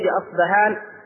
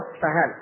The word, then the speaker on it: أصبهان: أصبهان عليهم الطيالس. الدجال يتبعه 70,000 من يهود أصبهان، أو أصفهان تنطق بالبي اللي يتبع في الفي الإنجليزية أو بالفاء.